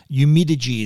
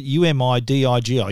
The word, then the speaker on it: Umidigi: And there are images of the side by side comparison of the accuracy of the phone, this this brand Umidigi, it's a Chinese brand. The it's the A9, the A9 Pro, and the A7S. I think that's how you pronounce it. U M I D I G I Umidigi.